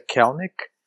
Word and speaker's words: Kelenic: apesar de se escrever Kelenic, a pronúncia é Kelenic, e assim, me parece ser a grande esperança para os próximos anos aí pro Mariners, né?